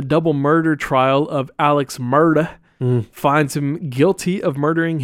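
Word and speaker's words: Murdaugh: Murda on June 7th, 2021. You got to say it like that. When I was watching the documentary, that's how they were, Murda.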